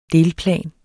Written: delplan

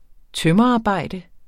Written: tømrerarbejde

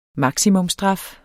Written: maksimumstraf

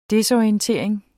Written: desorientering

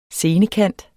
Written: scenekant

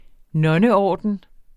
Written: nonneorden

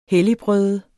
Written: helligbrøde

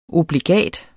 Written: obligat

